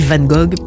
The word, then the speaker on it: Van Gogh: Et je dis Van Gogh parce que ça se prononce eh de la sorte.